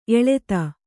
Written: ಎಲ್ಲಿತ್ತು

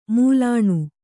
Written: ಮೂಲಾಣು

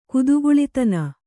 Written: ಕುದುಕುಳಿತನ

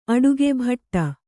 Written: ಭಾಟ್ಟ

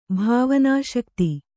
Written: ಭಾವನಾಶಕ್ತಿ

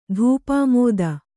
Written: ಧೂಪಾಮೋದ

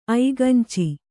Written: ಐಗಂಚಿ

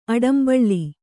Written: ಆಡಂಬಳ್ಳಿ

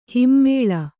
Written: ಹೆಮ್ಮೆಳೆ

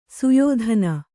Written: ಸಯದಾನ

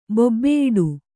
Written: ಬೊಬ್ಬೆಯಿಡು

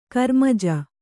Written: ಕರ್ಮಜ

